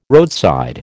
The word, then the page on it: roadside: American English